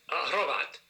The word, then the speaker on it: Hrovat: Hrovat. – Hrovat. – So Meta Hrovat. – Hrovat. – So they, all these Slovaks and Slovenian Croatians roll their R's, right? – Okay.